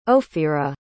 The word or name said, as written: ofira